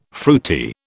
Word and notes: fruity: British English

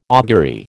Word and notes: augury: American English

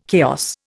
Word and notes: chaos: Indian

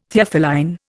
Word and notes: Theophylline: Indian

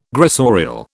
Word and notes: gressorial: British English

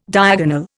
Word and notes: diagonal: Indian

